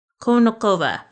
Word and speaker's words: Konnikova: So Maria Konnikova, pardon the pronunciation, Konnikova, okay, got it.